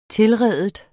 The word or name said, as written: tilride